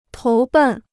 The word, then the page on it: 投奔: tóu bèn